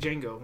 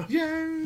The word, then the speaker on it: Django: Yeah, like Django. What am I thinking? All right. So Django! – The D is silent.